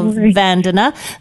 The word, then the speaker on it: Vandana: Vandana, and I said, I put the stressor on the wrong syllable, so I apologize. I said Vandana, so Vandana, thank you so much for joining us.